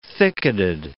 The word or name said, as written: thicketed